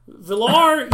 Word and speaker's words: Villar: Vayar, yes, because he... Why am I saying his name wrong right after you did it? Villar, yes, just because he went in like fourth, fifth round.